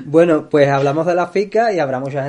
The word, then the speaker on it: fika: ¿Y qué es la FICA? Pues se escribe F-I-K-A. – Fica de toda la vida. Y ¿eh? se pronuncia Fica.